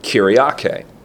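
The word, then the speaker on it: kuriake (κυριακή): You could spell that K-U-R-I-A-K-E, kuriake, meaning of the Lord.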